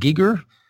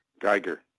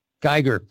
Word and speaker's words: Giger: And I never can pronounce this guy's name right, but at the art of H.R., is it Giger? – Geiger. – Geiger, the same, like an encounter, the same guy who did the design they used for Alien and on that.